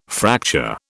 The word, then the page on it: fracture: British English